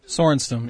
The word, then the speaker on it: Sorenstam: The top 10 reasons Annika Sorensen, how do you pronounce that name? Is it Sorenstam? – Sorenstam, yeah.